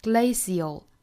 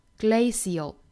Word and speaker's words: glacial: Move at a glacial pace, glacial 这个单词我们注意哈，中间 c 的这个字母呢，我们可以把它发成一个 sh, 也就是轻辅音 sh, 也可以选择把它发成一个 s 的发音 glacial, glacial 也是可以的。